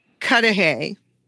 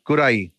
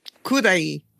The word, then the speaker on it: Cudahy: So I grew up hearing that it was called Cudahy, the super white version. What is the correct? – Oh, yeah. – What is the correct way? – Well, this is a Latino, Mexican, and Central American Latinx dominant part of the city. So even, you know, Bell Gardens is pronounced with a Spanish accent, you know, Bell. But so we just say Cudahy.